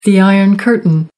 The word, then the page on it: iron curtain: American English